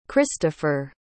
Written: cristofer

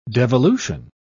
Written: devolution

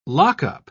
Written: lockup

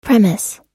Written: premise

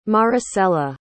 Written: maricela

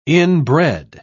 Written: inbred

